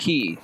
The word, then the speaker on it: quay: Yeah, Q U A Y key sounds like key.